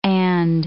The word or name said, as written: and